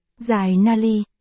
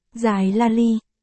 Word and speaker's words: Jai Nali: "Là Jai Nali, phát âm là Jai Nali, ở đây Andre phát âm thành Jai Lali, không có nghĩa."